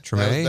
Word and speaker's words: Treme: I'm embarrassed that I don't know how to pronounce it. Is it Treme? – Treme.